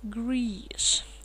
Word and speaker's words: grease: Grease. Интересное слово. Наверняка вы знаете слово Греция. Grease. Так вот, так же читается слово grease.